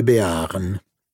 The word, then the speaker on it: Béarn: Dalla seconda avevo imparato all'età di 5 anni che non si dice Tarn, ma Tar, e non Béarn, ma Béar.